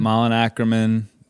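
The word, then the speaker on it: Malin Akerman: Malin Ackerman? – Yep. Malin Ackerman, Vanessa Lachey, Brent Morin.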